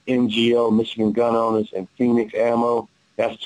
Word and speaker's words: Fenix: There is one person who stands out, and that's, you know, Phoenix Ammo. You guys familiar with Phoenix? – Oh, no, not, no. – And it's spelled F-E-N-I-X Ammo.